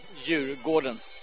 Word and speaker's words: Djurgården: Beautiful day in Stockholm, in the middle of Stockholm, on the Royal Park, pronounced Djurgården.